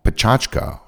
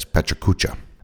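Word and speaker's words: Pecha Kucha: called Pachachka, sometimes mispronounced as Pachakucha.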